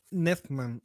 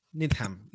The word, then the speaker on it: Needham: Em, el nombre de el hombre de acrobacias de Rick, Cliff Booth, es un homenaje a Hal Nedham. A ver, Hal Needham. No sé si se pronuncia correctamente, que fue eh, el doble de Burt Reynolds.